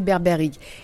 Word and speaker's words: BerbèrHygge: Berberig, c'est ça vous l'avez bien prononcé, Berberig.